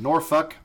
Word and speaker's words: Norfolk: Uh no, uh, before we went on break, we talked about Armed Forces Brewing out of Norfolk.